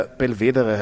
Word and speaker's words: Belvedere: Das prominenteste Beispiel in Wien ist das Schloss Belvedere, das tatsächlich italienisch ist und daher Belvedere heißen müsste. Also das E ist nicht stumm am Ende. Es wird aber tatsächlich immer Belvedere ausgesprochen, was eher französisch wäre.